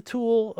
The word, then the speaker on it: Tul: Well, it's the tool pen, and then one of those. I can find it for notes, but you know the TUL, right? With the long U sound over it. Tool, TUL.